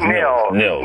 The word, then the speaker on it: Nils: Now, Niles, his name, is it pronounced Nils Shapiro or Niles? – Nils. Nils, kind of the American pronunciation. Yeah. – Nils without the E.